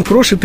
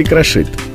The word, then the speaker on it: крошит: Да, в глаголе «крошить» ударение на корне не просто разрешено, но этот вариант считается предпочтительным. Крошит, крошат. Так говорит лучше. Крошит, крошат вариант допустимый, не ошибочный, но менее желательный.